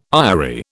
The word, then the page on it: aerie: British English